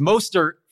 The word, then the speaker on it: Mostert: First off, as the number one fan, I must tell you to pronounce his name correctly. It's Mostert for one.